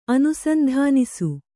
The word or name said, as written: ಅನುಸಂಧಾನಿಸು